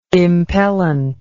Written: impellent

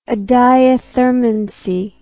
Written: adiathermanous

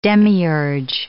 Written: demiurge

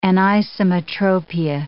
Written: anisometropia

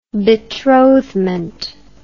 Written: betrothment

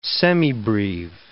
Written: semibreve

